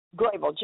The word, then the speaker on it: Grable: Is it Grable? Am I saying that correct? – Grable, G-R-A-B-L-E, right, Grable.